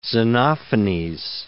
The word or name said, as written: Xenophanes